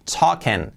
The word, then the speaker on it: talking: La L no se pronuncia, token. Y la ING que hemos visto antes, la I como una E, token. Veis que digo un poco token, Me O y Uh, uh, token, token, token, token, No es talking, token.